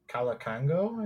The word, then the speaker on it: Kalukango: Uh, yeah, uh, uh, Joaquina Kalukango, I think it's how mm-hmm. her last name is pronounced.